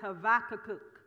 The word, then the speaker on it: Habakkuk: I pronounce his name Habakkuk. Okay, that's close, right? The Jewish or the Hebrew pronunciation is Habakkuk. Habakkuk. The B is a V.